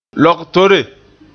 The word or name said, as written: logtore